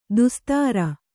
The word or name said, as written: ದೋಸ್ತಾರ